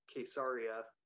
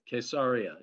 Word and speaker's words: Caesarea: Remember the Israeli pronunciation, because it's Caesarea. – Very good. Caesarea. Yeah, so it's a hard K.